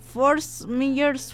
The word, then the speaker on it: Fort Myers: Saludos desde Fort Myers, Florida. ¿Lo pronuncié bien? – Sí, claro que sí.